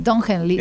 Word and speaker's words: Don Henley: para ver que no solamente los documentalistas abordan esto, hay canciones que lo hacen, como la de Don Henley, no sé si lo estoy pronunciando bien. – Don Henley, sí.